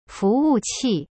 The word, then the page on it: 服务器: fu wu qi